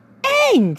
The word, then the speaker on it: Aang: It's supposed to be Aang, for crying out loud. They even pronounced it and showed it in the show. A-N-G, dummy. Aang.